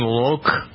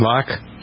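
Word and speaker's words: Loc: Anyway, did you know this guy's name was pronounced Tone Loke? – Loke. – Not Locke. Loke.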